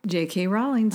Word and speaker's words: Rowling: J.K. Rowling.